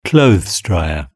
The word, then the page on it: clothes dryer: British English